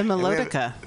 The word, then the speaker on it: Melodica: But anyway, to be continued in a few minutes, because here is our brother to introduce the Melodonia. Is that how you say it? – The Melodica.